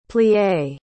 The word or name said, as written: plié